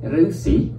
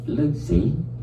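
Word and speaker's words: Rusi (ฤๅษี): Cho nên là cái chữ đạo sĩ á, ta đọc là rứ sĩ, Rusi, tiếng Thái là Rusi, nó từ tiếng Sanskrit là Rusi, Rusi. Tiếng Thái đọc là Rusi, ông đọc là Lư, Lư Sĩ.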